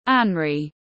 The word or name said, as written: anraí